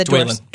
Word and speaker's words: Dwalin: On film and TV, he is best known for his roles as Dougal Mackenzie in Outlander, the fierce dwarf Dwalin in the, I hope I said that right, because I always miss the Dwalin. – Dwalin, thank you.